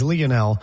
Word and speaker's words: Lionel: He is not Lionel. He's not Lionel. He's Lionel. That's how you pronounce it.